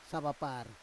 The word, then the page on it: savapar: Roon